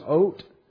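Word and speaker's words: oat (אות): The word is oat. O-T. That one's a hard one to transliterate because it starts with an aleph, which is not pronounced. You wouldn't say AOT because that's vowel in English. It's oat.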